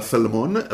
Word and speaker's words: salmon: שאין אל לא במילה סלמון ולא במילה אלמונד, אין אל. – אם האל באמצע לא אומרים אותה.